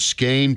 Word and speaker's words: skein: Well, I'll tell you, it refers you to the bottom of the page where the note, the word skein, pronounced skein in stanza 2, reminds us that we depend on God to untangle our sin-filled lives.